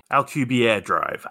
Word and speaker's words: Alcubierre: Now, there's ways around that that people are researching, like the Albuquerque Drive. Albu- Am I saying that right? actually, Alcubierre drive, actually, which is a theoretical warp drive.